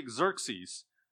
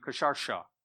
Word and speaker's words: Xerxes: So we're just going to use the Greek pronunciation. We're going to say Xerxes instead of saying Kasharsha, right?